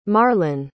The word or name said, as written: marlen